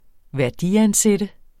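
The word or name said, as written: værdiansætte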